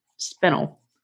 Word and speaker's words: spinel: I just realized I'm also saying the name wrong. It's a spinel.